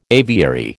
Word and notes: aviary: American English

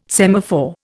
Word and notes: semaphore: Indian

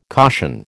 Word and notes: caution: American English

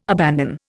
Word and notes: abandon: Indian

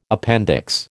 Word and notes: appendix: American English

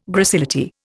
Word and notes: gracility: Indian

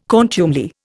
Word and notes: contumely: Indian

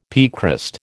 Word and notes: piecrust: American English

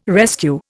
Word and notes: rescue: Indian

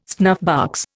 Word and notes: snuffbox: Indian

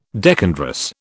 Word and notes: decandrous: British English

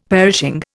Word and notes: perishing: Indian

